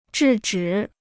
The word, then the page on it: 制止: zhì zhǐ